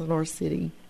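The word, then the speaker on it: Lenoir City: Anyway, Jennifer, we're excited about you're going to be here at Lenore City at First Baptist Lenore City. Is it right? I said it right. – That's right.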